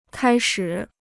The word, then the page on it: 开始: kāi shǐ